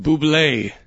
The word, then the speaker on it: Bublé: Who is Michael Bobbitt? – It's pronounced Bublé. – Bublé. – Bublé. – Oh, it's Bublé. I'm sorry. That's how good a guy is, Coach. You don't even say his name the way he is spelled.